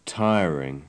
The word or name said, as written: tiring